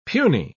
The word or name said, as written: puny